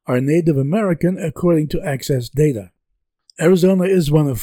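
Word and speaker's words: AHCCCS: The Medicaid program in Arizona is called the Arizona Healthcare Cost Containment System, or AHCCS, pronounced ACCESS.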